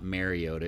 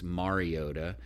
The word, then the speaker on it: Mariota: They kept saying Marcus Mariota. It's not Mariota. It's Mariota.